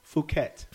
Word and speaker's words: Phuket: Tell me how to say it. Is it Phuket? – Phuket. – Phuket. – Yeah.